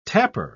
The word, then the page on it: tapper: British English